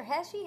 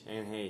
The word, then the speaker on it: Heche: Is it Heche or Heche? How do you say it? – Heche. Anne Heche.